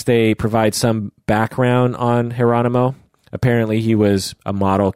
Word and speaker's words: Geronimo: So think Geronimo, but with a J, pronounced Geronimo.